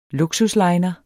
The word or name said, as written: LUKSUSLINEREN